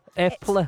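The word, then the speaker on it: Eple: Epla. – It's apple, but it's... – It means apple in Norwegian.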